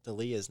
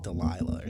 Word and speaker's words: D'Elia: Yeah, you also said Chris D'Elia's name wrong. It's D'Elia, not whatever. You were like Delilah or something like that. It's D'Elia.